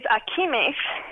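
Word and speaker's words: ajimez: My personal favorite is Akimesh, which is a twin window derived from Islamic architecture. And that's how it's pronounced.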